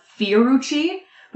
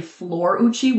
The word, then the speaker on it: Florucci: We were saying it as Fiorucci, but it's actually Florucci with an L.